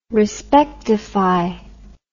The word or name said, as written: respectify